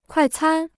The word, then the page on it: 快餐: kuài cān